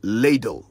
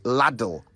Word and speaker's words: ladle: And it's ladle with one D, not laddle with two Ds. L-A-D-L-E, ladle.